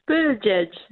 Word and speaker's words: Buttigieg: And she wrote an article in the New York Post how Pete, is it Buttigieg? How do you say it? – Buttigieg. It's like saying "edge" twice.